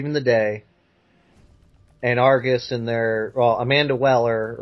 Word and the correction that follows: Waller: or Wheeler, whatever the hell you pronounce her last name, is going to be like, yeah. – Or actually, it's Waller.